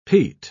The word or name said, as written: peat